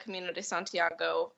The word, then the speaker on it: Camino de Santiago: And you hiked a pretty big trail this summer, the Camino de Santiago. Is that how you pronounce it? – Yeah. So, yeah, I did the Camino de Santiago just about a month ago.